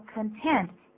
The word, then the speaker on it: content: 可是请注意哦，这个同样的拼法，你如果把重音放在第二个音节的话，就念作 content，意思就完全不一样了，就变成满足的意思。